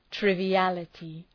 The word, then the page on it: triviality: ,trıvı’ælətı